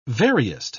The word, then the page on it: veriest: British English